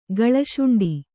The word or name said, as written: ಗಳಶುಂಡಿ